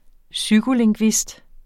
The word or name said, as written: psykolingvist